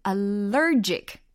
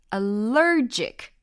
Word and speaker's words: allergic: 그리고 지금 allergic, allergic 이라는 것은 이 이음절의 강세가 딱 느껴지셨죠?